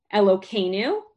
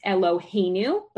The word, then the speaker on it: Eloheinu: Elokeinu, which actually is supposed to be pronounced Eloheinu, but when we're saying it in conversation and not as part of the blessing, we change one of the letters so that we're not saying another one of God's names, which means our God.